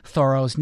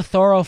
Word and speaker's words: Thoreau: You may say it Thoreau. That's how I usually say it. A lot of people say it that way. But apparently the folks in Concord who still know the Thoreau family pronounce it Thoreau. So that's what we did in the interview.